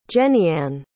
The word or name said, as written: jennyann